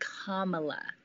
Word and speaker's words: Kamala: All right, so Joe Biden picked Kamala Harris, and her name is pronounced Kamala.